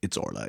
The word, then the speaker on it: Orla: And not Orla, which is... to vomit.